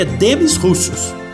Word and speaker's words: Demis Roussos: A pronúncia certa, vocês sabem que é Demis Roussos, não é Demi Rousseau.